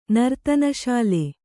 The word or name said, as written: ನರ್ತನಶಾಲೆ